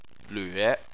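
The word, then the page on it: lve: tone 3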